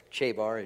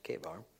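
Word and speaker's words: Chebar: And that river Kabar, or Chabar, as you'd see it there, but I think it's pronounced Kabar, means a time far off.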